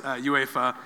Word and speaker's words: UEFA: I said it right for once. UEFA. – There you go.